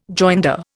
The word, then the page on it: joinder: Indian